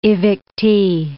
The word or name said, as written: evictee